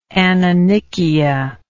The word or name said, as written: anonychia